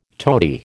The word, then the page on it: toady: American English